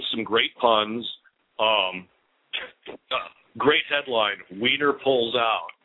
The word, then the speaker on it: Wiener: And they're pronounced Wiener and Weiner in German.